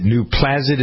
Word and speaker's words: Nuplazid: Is it Nuplazid? Is that how you pronounce it? N-U-P-L-A-Z-I-D? – Yes.